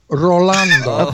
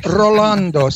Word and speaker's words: Rolando: Say my first name correctly. – Ronald. – No, Rolando. – Okay, whatever. – It's Rolando. Say my name. – Rolando. – Rolando. – So hold on. – Roll those R's, Jimmy.